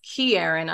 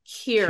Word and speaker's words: Kieran: So for those who aren't aware of the casting, how is his name pronounced? Is it Kieran? – Kieran Culkin. – I don't think it's Kieran. I just think it's Kieran.